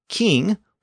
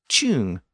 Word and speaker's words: king: It was usually spelled C-Y-N-G in late Old English. But the C at the front was still pronounced with a hard K sound. So when the scribe wrote C-Y-N-G, it looked like it should have been pronounced chung, not king.